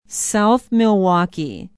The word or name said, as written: south milwaukee